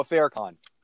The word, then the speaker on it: Farrakhan: Noah Farrakhan.